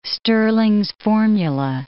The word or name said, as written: Stirling's formula